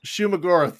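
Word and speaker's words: Shuma-Gorath: The first fight where they're fighting not Shuggoth Goroth, whatever, however you say his name, Shuggoroth, whatever. – Shumagoroth, there it is.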